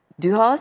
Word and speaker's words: Düha: E, bu çok e, Düha suresi, İnşirah suresi, Tin suresi. Evet. Doğru telaffuz ediyorum çok doğru, değil mi? Çok doğru.